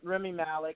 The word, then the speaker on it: Rami Malek: Uh, according to Collider, Oscar winner Remy Malek, I could be saying his name wrong. Nick usually always lets me know. Um, Remy Malek Not bad.